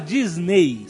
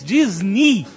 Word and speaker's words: Disney: O EY no final da palavra de Disney. Na verdade, não se fala Disney. Se fala em inglês, na pronúncia em inglês, Disney. tem o som de I.